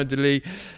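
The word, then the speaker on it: Delie: He's from Wallonia, so I think I can pronounce it in French. Renard, Arnaud Delis. Lee spelled L-I-E, so literally Arnaud of Lies.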